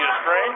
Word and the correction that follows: Strine: And, that is Kenzie Strine with a long I.